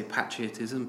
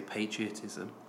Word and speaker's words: patriotism: It's one of those words where it's different between US and British English. So I say patriotism, but Americans say patriotism.